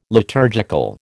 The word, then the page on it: liturgical: American English